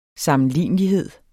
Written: sammenlignelighed

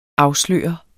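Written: AFSLØRER

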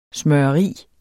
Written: smøreri

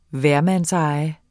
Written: hvermandseje

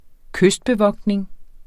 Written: kystbevogtning

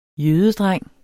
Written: jødedreng

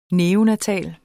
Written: neonatal

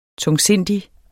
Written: tungsindig

